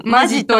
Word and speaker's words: 本気: ということで、今回は、本気で、本気と書いて、マジと読む。